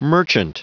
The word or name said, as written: merchant